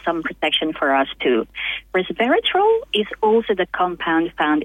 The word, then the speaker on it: resveratrol: You did mention this morning about resveratrol.